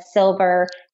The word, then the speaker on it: Sylver: My name is spelled Brianna, B-R-I-A-N-N-A, and then Sylver, S-Y-L-V-E-R.